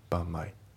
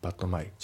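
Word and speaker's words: but my: で、あと発音ですけど、バットの T が抜けますね。バンマイ。バットマイじゃなくてバンマイ。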